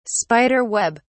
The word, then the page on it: spider web: British English